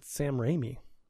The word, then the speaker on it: Raimi: Oh my gosh, it's Sam Raimi.